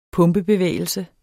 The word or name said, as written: pumpebevægelse